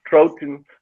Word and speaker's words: Trojan: Trojan.